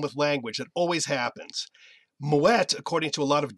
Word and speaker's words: Moët: So the name, the temptation is to pronounce it as you think French is pronounced. That would be moe. But in this case, the name is Dutch, so it's moet.